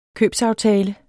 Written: købsaftale

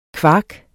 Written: kvark

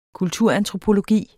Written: kulturantropologi

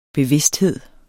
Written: bevidsthed

